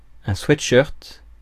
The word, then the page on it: sweatshirt: France